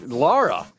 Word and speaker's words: Lara: Oh, well, and Laura. – And I think that's an appropriate image. Lara, be sure. – Yeah, goddamn. – Don't ever say that wrong, or I hope you die.